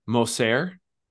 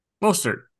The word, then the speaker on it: Mostert: He kept calling this football player, the running back, Moser. – Mostert. – Yes. – Yeah. He's French. – I'm like, there's, you're missing a T. Even with the pronunciation, you're still missing it. Like, if you thought it'd just be like Mostert, yeah, then you'd say Moser.